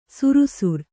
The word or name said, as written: ಶರಾಸಾರ